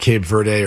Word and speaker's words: Cape Verde: I'm seeing, by the way, it's pronounced both ways. It's pronounced Cape Verde or Cape Verde, but the Portuguese pronunciation is Cabo Verde.